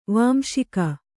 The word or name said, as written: ವಂಸಿಗ